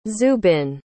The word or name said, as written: zubin